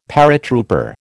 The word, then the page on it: paratrooper: American English